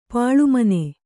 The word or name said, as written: ಪಾಳುಮನೆ